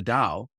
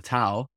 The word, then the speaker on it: Tao: So how could the Tao, or the Tao, one can pronounce it either way,